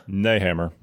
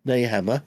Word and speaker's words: Nehammer: Karl Neth- Nethammer. – Nehammer. Yeah. – Nehammer. That's it.